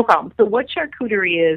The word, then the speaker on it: charcuterie: Now, you did mention a word in there, the charcuterie. Is that how you pronounce it? – Mm-hmm.